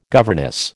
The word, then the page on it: governess: American English